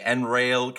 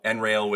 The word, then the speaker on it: Enraile: And finally, Enrail came out. Enrail, which is spelled Enraile, came out with Screen Time.